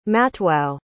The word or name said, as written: matwau